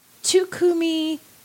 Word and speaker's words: Takuminuri: I was saying, I think, Takuminuri. I didn't know how to say it. So this person has laid out exactly how to say it. And it was about the color. And if I got it right, it's Takuminuri. Is how it should have been said, which doesn't look at all like what the word looks like to me as an English speaker.